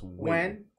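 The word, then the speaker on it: Nguyen: That word looks like it says Nugent. It's pronounced Wen.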